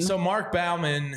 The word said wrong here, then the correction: Bowman: who covers, or Bowman, excuse me. – See, I would say Bowman. – I grew up with somebody that last name. They pronounce it Bowman. So we'll go with Bowman.